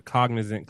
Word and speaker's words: Cognizant: The Cognizant Classic.